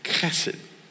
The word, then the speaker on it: chesed: The word is spelled H-E-S-E-D, but we pronounce it Chesed.